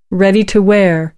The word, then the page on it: ready to wear: American English